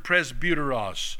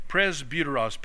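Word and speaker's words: presbyteros (πρεσβύτερος): It's the Greek word presbyteros. Presbyteros. P-R-E-S-B-U-T-E-R-O-S.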